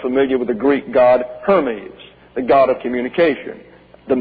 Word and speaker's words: Hermes: That's H-E-R-M-E-S. That's an eta down here, so it's Hermes. But you know him as Hermes, and that's spelled in the English H-E-R-M-E-S.